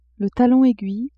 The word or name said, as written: talon aiguille